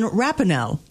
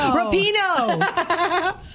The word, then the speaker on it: Rapinoe: So today's words come from Megan Rapinoe. Did I say her name correctly? – Nope. Rapinoe.